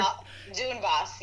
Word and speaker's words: Donbass: Donbass, yes.